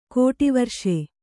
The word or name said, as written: ಕೋಟಿವರ್ಷೆ